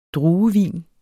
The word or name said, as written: druevin